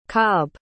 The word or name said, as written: cobb